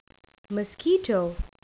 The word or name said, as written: mosquito